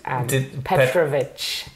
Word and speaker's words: Petrovic: Petrovic. – Petrovic is perfect. My grandfather's Serbian, so that's where Petrovic comes from.